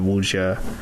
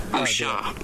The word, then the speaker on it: Wuxia: Here's I don't know what this is going to sound like, but this is the Oxford Online Dictionary pronunciation of Wuxia. – Wuxia.